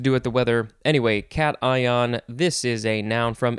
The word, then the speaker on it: cation: Next is now I would want to pronounce this, uh, you know, uh, Cashin, Cashin, uh, just with my normal knowledge of English, but it is actually cation, cation, something like that.